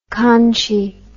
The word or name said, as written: conshy